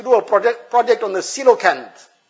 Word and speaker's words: coelacanth: It's the pronunciation is coelacanth, but it's spelled very differently. Person looks at the spelling, he'll be puzzled how to pronounce it.